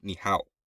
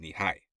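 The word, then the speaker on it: ni hao (你好): However, it says it's pronounced ni hao, not ni hai.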